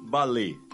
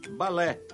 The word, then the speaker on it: balé: A pronúncia correta em português é aberta, balé. Acho que nosso cantor pensou que, por se tratar de palavra francesa, em que o E, com acento agudo, é pronunciado fechado, devesse também dizer balé. Equivocou-se. O certo é mesmo balé.